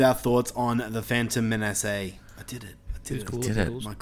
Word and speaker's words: Menace: How did you pronounce it? The Phantom... – Menace. – Okay. Yes. I'm not going to say it like that again.